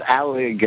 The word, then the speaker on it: Alig: Yes, Michael Elijah, if I got the name right, Michael Aya. – I think it's... Just Alig, I think. – Yeah, A-L-I-G. – Not Elijah.